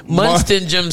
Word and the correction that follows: Munchausen: Syndrome. You know where the parent. Munchausen Syndrome.